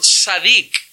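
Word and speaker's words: tzadik (צדיק): La palabra hebrea aquí es una palabra que se pronunciaría más o menos tzadik. Que significa justo, significa recto, significa derecho.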